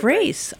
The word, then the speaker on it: Grais: Oh, regular like Grace. Grace. I love it. Mm-hmm. Like Grace. It's spelled G-R-A-I-S.